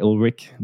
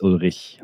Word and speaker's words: Ullrich: I like to make it easy for English-speaking hosts, so I say Ulrich, but actually not German, it's Ulrich, that's true, yeah. But nobody can pronounce that, so I don't even try anymore.